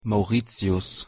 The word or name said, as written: Mauritius